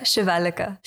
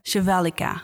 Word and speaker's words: Shivalika: Um, so, welcome Dewi en Shivanika. right? Do I pronounce it correctly? Yeah, Shivalika. Shivalika.